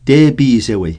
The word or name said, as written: tepisewey